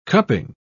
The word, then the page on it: cupping: British English